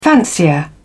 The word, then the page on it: fancier: British English